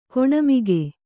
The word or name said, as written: ಗುಣಮುಖ